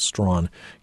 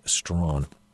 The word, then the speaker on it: Strachan: By the way, if you go and look for the book, his last name is spelled S-T-R-A-C-H-A-N, pronounced Strawn, Hugh Strawn.